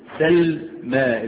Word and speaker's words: سلمى: سلمى، الميم مفتوحة والياء ترسم ياء ولكن تنطق ايه؟ ألفا، تنطق ألفا.